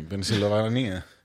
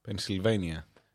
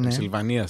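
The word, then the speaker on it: Pennsylvania: Πενσιλβανία. έτσι προφέρεται, έτσι λέγεται. Πενσιλβάνια. – Ναι. Πενσιλβανία στα ελληνικά.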